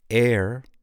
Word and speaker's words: heir: What about the silent H? Can you think of a couple words that use the silent H? Honest. Air. Air. Wait a second. How do you spell air? H-E-I-R.